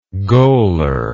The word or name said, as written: goaler